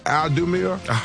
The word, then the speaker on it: Audemars: Audemars.